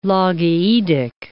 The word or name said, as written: logaoedic